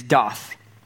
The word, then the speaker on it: daath: The Hebrew word for know, uh, and it won't be in your notes, but the Hebrew word for know is daath. D A A T H, if you're going to try to spell it phonetically in English, but it means to discover to know by experience.